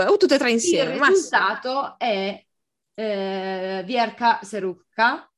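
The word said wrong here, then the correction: Serduchka: eh, no, Serdiucca, scusate, Serducca. – Eh, o Verka Serducca, dipende dalla pronuncia se russa o ucraina.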